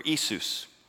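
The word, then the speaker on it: Isus (Ἰησοῦς): Now in the New Testament, which is written in Greek, the word Yeshua is translated in Greek, Yeshua, or Isus is actually how it's pronounced.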